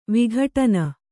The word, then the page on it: ವಿಘಟನ: vighaṭana